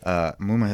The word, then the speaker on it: Muma: Nice job with the pronunciation. Muma has gotten quite a few different pronunciations, and that is spot on.